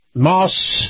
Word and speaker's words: Maus: Now, Moss is spelled M-A-U-S, Nissan.